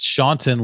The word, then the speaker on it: Shaunton: My husband's name is pronounced Shaunton like wanton soup.